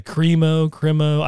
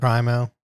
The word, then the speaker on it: Crimo: His name was Robert Crimo, C-R-I-M-O. Is it Crimo? I don't watch the news, so I don't know how they're pronouncing it. – Crimo.